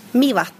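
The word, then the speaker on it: Mývatn: So you work here at Mivatn? Mivatn? – Yes. – How do you say it? – Mivatn.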